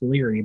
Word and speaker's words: Lire: It's pronounced Leary, but it's spelled L-I-R-E.